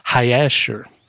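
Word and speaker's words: Hayashar: It's actually pronounced because there's no J in the J sound is not in the Hebrew language. – In the Hebrew language, it's called Hayasher.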